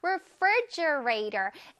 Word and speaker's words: refrigerator: 다시 한번 refrigerator. F-R-I 부분에서 이제 강세를 주는 거 기억하지요?